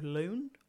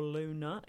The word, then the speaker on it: Lune: And then another day we went to this bakery called Loon or Luna. It's spelled L-U-N-E.